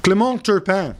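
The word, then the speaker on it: Clément Turpin: Clement Turpin. – Well done. – Did I pronounce it correctly? Yeah.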